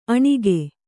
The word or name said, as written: ಅನಕು